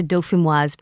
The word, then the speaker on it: Dauphinoise: Yeah, so these are your Dauphinoise potatoes.